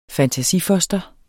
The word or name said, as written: fantasifoster